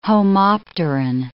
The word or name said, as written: homopteran